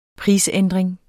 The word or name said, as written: prisændring